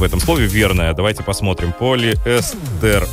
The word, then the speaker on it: полиэстер: Полиэстер на «э», на букву «э». Так как слово пришло из английского языка, где ударение падает на гласную в корне. Вариант с ударением на последний слог считается ошибочным, хотя иногда встречается разговор вещи.